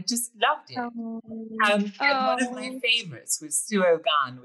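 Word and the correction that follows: Suo Gân: which I'm probably horribly mispronouncing. – Oh, no, you're really close to Siogan. – Siogan.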